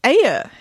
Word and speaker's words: Ayer: And that director is David Ayer.